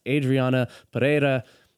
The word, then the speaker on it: Adriana Perera: And today we have an awesome guest, Adriana Perera.